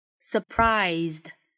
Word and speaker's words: surprised: 首先，在读这个单词的时候要注意 S 的发音，我们说结尾的 S 它读成 z，所以是 surprised。